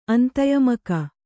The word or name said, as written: ಅಂತಯಮಕ